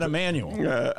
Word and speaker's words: Emanuel: Man, did you see Babs giving me grief on Facebook for mispronouncing Manuel's name? – Oh, Jeff Manuel? – Yeah, I got to give Babs credit. How many times? – I mean, you just did it again. Yeah, you said Emanuel.